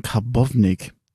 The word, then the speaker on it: Karbownik: Äh, vor allem mit dem Debütanten Karbovnik. Habe ich das richtig ausgesprochen? Ja.